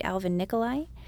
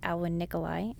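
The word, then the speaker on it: Alwin Nikolais: And I also really enjoyed Alvin Nikolai at the Joyce Theater. Sorry, that's actually pronounced Alvin Nikolai at the Joyce Theater.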